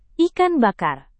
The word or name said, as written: ikan bakar